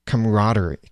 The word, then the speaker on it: camaraderie: And what's even better than that I love how we have this camaraderie. Camaraderie. I, I, actually learned from the dictionary today that there's an extra syllable in there that's supposed to be pronounced.